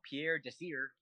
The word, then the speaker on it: Pierre Desir: Yeah, the guy next to him was some dude from the practice squad called Pierre Desir. – Yeah. – I don't know if you said that right. – No, you got it, yeah.